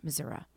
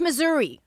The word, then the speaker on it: Missouri: aside from it's not pronounced Missouri. The only people that pronounce it Missouri are people from out of state who are trying to campaign there for whatever reason. It's Missouri. Okay, we got that over with and out of the way.